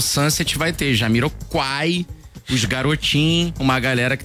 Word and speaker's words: Jamiroquai: Não, é porque eu falei Jamiroquai, mas é Jamiroquai, eu li errado. Troquei o L pelo U.